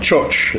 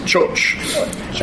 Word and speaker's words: txotx: They call it choch. It's spelled T-X-O-T-X, but it's pronounced choch.